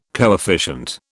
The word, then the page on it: coefficient: British English